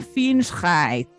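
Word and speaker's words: Fingscheidt: Eh, ella se llama Nora y Google me dice que se pronuncia que se pronuncia, perdón, Nora Finchheit.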